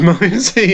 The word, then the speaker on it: Ramones: Ramones, is that how you pronounce it? – Ramones, yeah.